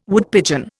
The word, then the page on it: Woodpigeon: Indian